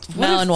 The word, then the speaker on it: Malinois: That's Malinois, fucking that's how you say it.